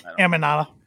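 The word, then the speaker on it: Aminata: And Queen Empanada. Am I saying that right? Like empanada, like empanada, like the Emanata. – Emanata. – Emanata. – There's no P in there, huh?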